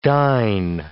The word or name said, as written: dine